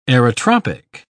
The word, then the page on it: aerotropic: British English